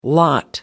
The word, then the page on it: lot: American English